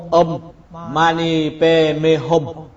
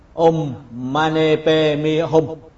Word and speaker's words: Om Mani Padme Hum: Phát âm của tiếng Tây Tạng là Om Mani Padme Hum, Om Mani Padme Hum.